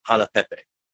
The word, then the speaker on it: Hanapepe: Right now, I'm actually in another town called Hanapepe.